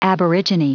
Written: aborigine

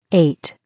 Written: 8

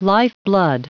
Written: lifeblood

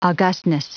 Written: augustness

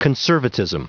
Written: conservatism

